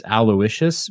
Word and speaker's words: Aloysius: How do you say that? – It's Aloysius, but they spell it like Aw Yeah, but it's like Aloysius.